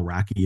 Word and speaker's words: Racki: Kyle Racki is the name, R-A-C-K-I, pronounced wacky.